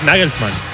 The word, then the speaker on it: Nagelsmann: Es Nagelsmann. – Claro, es Nagelsmann.